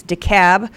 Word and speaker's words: DeKalb: and it's DeKalb. You do not pronounce the L in DeKalb, Nick.